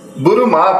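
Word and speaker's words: Bulma: Burumá, por exemplo, que é a Buma, né, que a gente conhece, a pronúncia é Burumá, é um calção que as meninas usam para jogar vôlei, esportes, aquele calção bem apertadinho.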